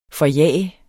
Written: forjage

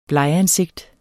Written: blegansigt

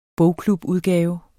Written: bogklubudgave

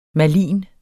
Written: malign

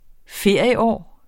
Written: ferieår

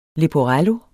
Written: leporello

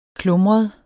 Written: klumret

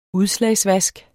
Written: udslagsvask

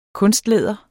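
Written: KUNSTLÆDER

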